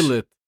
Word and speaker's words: Regolith: Und wie ist spricht man dann. das aus auf Englisch? Regolith. – Habe ich doch gesagt. – Hab also, nee, also, also, einfach, also Regulit ist das, ja.